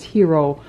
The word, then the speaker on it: Hiro: In fact, I have a story today about a Japanese-American boy whose name is Hiro, only it is spelled H-I-R-O.